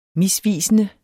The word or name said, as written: misvisende